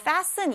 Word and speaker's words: fasten: fasten 이 단어 자체 가운데에 t가 들어가 있지만 이 t는 발음이 되지 않아요. 무음이에요. 그래서 fasten이 아니고 fasten, fasten 이렇게 발음하시면 됩니다.